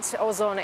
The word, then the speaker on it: Ozon: What's the significance of, you know, companies that are being attacked like Wildberries and now Ozon? – Yeah, that's right. So you said it right. Ozon is Russia's second largest e-commerce retailer.